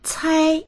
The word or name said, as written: cai1